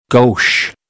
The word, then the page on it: gauche: American English; oʊ - əʊ